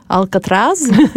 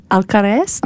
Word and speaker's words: Alcaraz: Alcatraz. Hva heter han som vant? – Alcaraz.